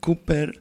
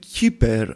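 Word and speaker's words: Kuepper: Η λατρεία, ο Ed Kepper ή Cooper ή Ed Kepper, όπως ο ίδιος προφέρει τον εαυτό του, κρατώντας μια γερμανική προφορά.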